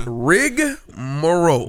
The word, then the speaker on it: rigmarole: Listen, guys, it's not an A after the G. Nah. It's rigmarole.